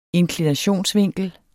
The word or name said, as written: inklinationsvinkel